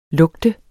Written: lugte

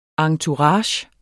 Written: entourage

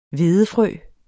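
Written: hvedefrø

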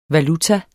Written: valuta

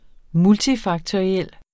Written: multifaktoriel

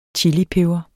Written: chilipeber

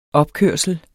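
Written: OPKØRSLER

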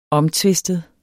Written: omtvistet

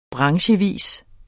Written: branchevis

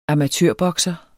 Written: amatørbokser

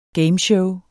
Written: gameshow

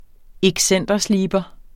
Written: excentersliber

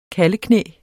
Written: kalveknæ

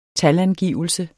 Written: talangivelse

